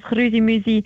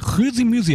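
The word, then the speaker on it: Chrüsimüsi: Grüßimüsi, habe ich das richtig ausgesprochen? Es klingt richtig gut.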